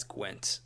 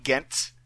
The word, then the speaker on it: Ghent: Ghent. Yeah. I saw that name and I was like, I'm going to mispronounce that as Gwent at one point.